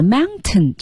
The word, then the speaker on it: mountain: 对，其实本来 mountain 这个单词呢，在口语里是要喉音停顿的，要读成 mountain。